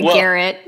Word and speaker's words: garrote: Yeah, and the funny thing is, I said Garrett in my video too. I've since corrected my pronunciation.